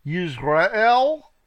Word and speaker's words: Jezreel: Verse 3, and these were of the father of Etam, Yisrael, that's not like Yisrael, it's spelled Jezreel, and Yishma, and Yidbash, and the name of their sister was Hatzelelponi.